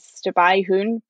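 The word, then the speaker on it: Stabyhoun: Well, in theory, it's supposed to be pronounced the Dutch way, which is Stabby Hound,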